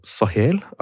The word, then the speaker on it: Sahel: Du har en årrekke nå arbeidet med spørsmål knyttet til krig, konflikt og samfunn I Nord-Afrika og Sahel. Det riktig uttale? – Ja. – Ja.